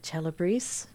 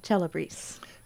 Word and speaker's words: Celebris: How do you spell Celebrise? – C-E-L-E-B-R-I-S. – C-E-L-E-B-R-I-S. – It's Latin, so it has to be that ch sound at the beginning. If you're going to do Italian Latin, you know.